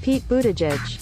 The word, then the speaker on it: Buttigieg: Pete Buttigieg.